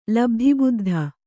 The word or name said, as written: ಲಬ್ಧಿಬುದ್ಧ